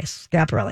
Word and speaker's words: Schiaparelli: Schiaparelli. – Schiaparelli. Mm-hmm. Okay, I do too, but I wasn't sure if it was supposed to be Schiaparelli or whatever. Okay, Schiaparelli.